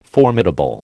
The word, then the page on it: formidable: American English